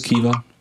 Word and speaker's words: Caoimhe: C-A-O-I-M-H-E or close. Kiomi, pronounced Kiva. Kiva, it's an Irish name.